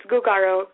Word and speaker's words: Gugaro: Is it pronounced Gugaro? – Absolutely. It's Gugaro. Guga for the sound that kids make all the time.